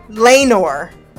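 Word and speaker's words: Laenor: Is that, well, with Lanair. Lanair? Now you got me fucked up on the name. Lanor. Lanor is how you pronounce it.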